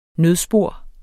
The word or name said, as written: nødspor